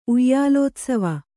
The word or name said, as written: ಉಯ್ಯಾಲೋತ್ಸವ